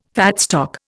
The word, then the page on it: fat stock: Indian